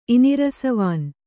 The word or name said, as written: ಇನಿರಸವಾನ್